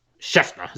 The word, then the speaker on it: Schefter: Schefter, is that how you say his name? – Yeah.